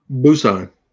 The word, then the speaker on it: Busan: Busan.